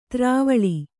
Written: ದ್ರಾವಿಳ

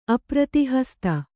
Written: ಅಪ್ರತಿಹಸ್ತ

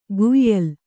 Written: ಕುಯ್ಯಲ್

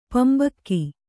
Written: ಪಿಂಬಕ್ಕ

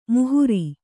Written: ಮೋಹರಿ

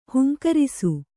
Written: ಹೂಂಕರಿಸು

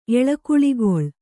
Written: ಎಳೆಕುಳಿಗೊಳ್

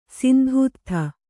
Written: ಸಿಂಧೂತ್ಥ

